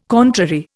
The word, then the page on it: contrary: Indian